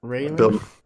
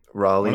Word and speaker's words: Raleigh: Rally. Or how do you say his name? – Rayleigh. – Some G's and H's. – St. Clair. Yeah, Raleigh? Absolutely. Raleigh.